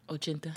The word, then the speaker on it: ochenta: Shout out to Ocho Chinta. Did I say it right? – Ochenta. – Damn it. I said 880.